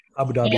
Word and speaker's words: Abu Dhabi: The most, I didn't realize until I watched the CPSDA takeover when you and Charles went to, was it Abu Dhabi? Is that how you say it? – Yeah, Abu Dhabi.